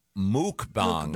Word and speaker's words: mukbang: It's actually pronounced muk. Yeah. – Is it muk? – Mukbang. – Yep. No, I thought it was mukbang. – The pronouncer here is M-O-O-K. – Oh, okay. I've always heard muck. The people who do it, I've heard say muck. – It's spelled M-U-K-B-A-N-G.